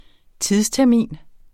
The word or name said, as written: tidstermin